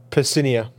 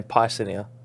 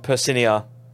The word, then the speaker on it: Percinia: How do you say this? – I heard it from Ollie, and okay. It is Percinia. – It is Pis... Say it again. – Percinia. – Percinia. – Yeah. – What were we saying? Piscinia. – Piercinia or something like that. I think we're putting too much emphasis on the PYR. Too hard. Yeah, we're trying too hard. – Percinia. – It's Percinia.